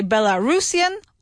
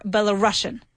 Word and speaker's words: Belarusian: But turns out that you can say Belarusian or Belarusian. Both pronunciations are correct.